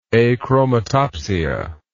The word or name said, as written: achromatopsia